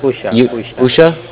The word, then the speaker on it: Usha: You like umbrella, S-H-A. – Right. Is it, how do you pronounce it? Usha? Yusha? – Usha. Rani. R-A-N-I is her last name.